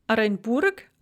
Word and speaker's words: Оренбург: So for example, in the names Оренбург or Арарат, both the O and the A there at the beginning of the words are pronounced A, Оренбург, Арарат.